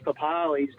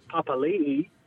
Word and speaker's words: Papali'i: somehow Josh Papali's gone to Josh Papali'i. Don't know when that happened.